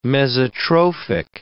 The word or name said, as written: mesotrophic